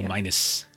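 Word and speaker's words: Annúminas: Gilly would like to do like a lore check about like black sailed ships and Unima, Anumas, or however you say it. – Anuminas. – How do you say it? Anuminas. Thank you. – Anuminas. It's like Minas Tirith. And then you just do Anu.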